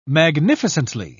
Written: magnificently

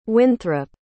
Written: wynthrop

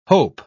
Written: hope

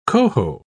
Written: coho

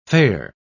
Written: fare

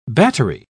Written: battery

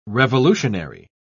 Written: revolutionary

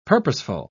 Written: purposeful

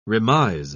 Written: remise

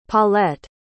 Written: Paulette

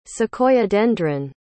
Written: Sequoiadendron

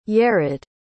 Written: yered

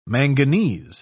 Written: manganese

